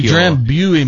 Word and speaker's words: Drambuie: I see Drambuie must be the British pronunciation because Drambuie. We call it Drambuie.